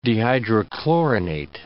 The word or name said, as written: dehydrochlorinate